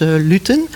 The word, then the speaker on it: Luten: De Heks van Limbricht, dat is uh, eh, Engen eh, Luiten of Luten. Ze kwam uit een ander dorp, uit uh, eh, Lutterade. Dus waarschijnlijk werd, werd het toen uitgesproken als Luten.